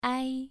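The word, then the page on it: ai: tone 2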